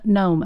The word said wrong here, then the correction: Noom: I probably say it wrong. Noom.